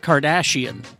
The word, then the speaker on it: Cardassian: Kardashian? No, I said it wrong. Ducat Cardassian is the name of the episode.